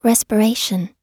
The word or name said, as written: respiration